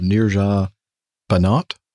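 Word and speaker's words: Neerja Bhanot: After listening to another one of Nick's amazing plane tales about the courageous and sad story of Nirja Banat, or... Is that the right way to pronounce it? – I think it is, yes.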